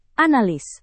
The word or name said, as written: analis